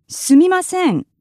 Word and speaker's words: すみません: The Japanese phrase for apologizing is すみません.